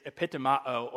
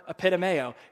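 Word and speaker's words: epitomeo (ἐπιτιμάω): But the Greek word, this is great for all you Greek nerds out there, the Greek word for rebuke here is epitomao, or if you want to pronounce it in a way that you will remember, epitomeo. It's like a pit of mayo.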